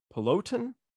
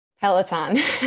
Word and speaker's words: Peloton: She's designed for artists and brands including James Taylor, Aerosmith, Hootie and the Blowfish, Darius Rucker, Simone Biles, Nike, Bloomberg, Peloton. Did I say it right? – Peloton.